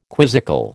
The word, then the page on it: quizzical: American English